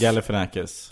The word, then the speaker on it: Galifianakis: Men Zack var då en av dina, jag kan inte uttala hans efternamn, Galifianakis. – Galifianakis. – Galifianakis.